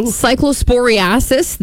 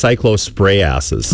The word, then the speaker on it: cyclosporiasis: Cyclosporiasis, the diarrhea. – You're saying it wrong. – Am I? – Yeah. It's pronounced cyclosporiasis. – Oh, I'm sorry. – That's the scientific pronunciation.